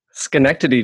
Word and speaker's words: Schenectady: Let's see, in Schenectady, New York. Is that right? – Schenectady, New York.